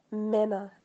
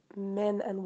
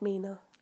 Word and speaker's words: Mena: But a heads up that my name is pronounced Mena, as in men and women. Mena, not Mina. Mena.